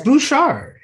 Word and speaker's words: Bouchard: It's Bouchard. He's living in Canada. Well, that's how they say it.